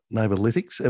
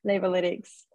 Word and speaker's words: Neighbourlytics: Yeah, um, we'll come to that, but can you just explain, you know, neighbor Neighbourlytics? Am I pronouncing it correctly? – That's right. Yep, Neighbourlytics.